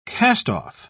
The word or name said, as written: cast-off